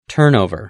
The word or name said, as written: turnover